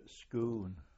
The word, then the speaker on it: Scone: That was at Scoon. – Scoon? – Yes. – How do you spell that? Sorry. – S-C-O-N-E. That's the Scottish.